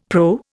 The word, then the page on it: PRO: Indian